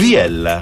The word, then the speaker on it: Viella: Viella. – Viella, ok. – Viella, come Biella con la V anziché la B.